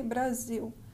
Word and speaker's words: Brasil: And remember in Portuguese, Brazil is spelled with an S, not a Z. So we say Brazil, but they say Brazil.